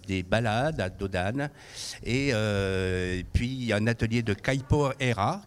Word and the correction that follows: Capoeira: Caipoeira, si je le prononce bien. – Capoeira. – Ah, Capoeira.